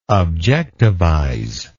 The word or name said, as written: objectivize